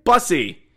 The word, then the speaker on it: Bussi: And out of Providence is being led by Bussy. That's not what it sounds like. Brandon Bussy. B-U-S-S-I. It's pronounced exactly like it's spelled.